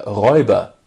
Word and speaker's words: Räuber: The robber, der Räuber, is coming to get ya. Räuber rhymes with Klaus Teuber, and this is how you can remember which guy actually invented this game.